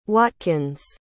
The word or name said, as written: watkins